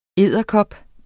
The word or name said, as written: edderkop